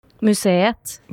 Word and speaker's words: Museet: Musette, is that how you pronounce it? – Museet. – National Museet. – Museet. Yeah. Okay, yeah. I was like, it's not a museum. It's got a T at the end.